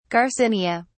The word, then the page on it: garcinia: British English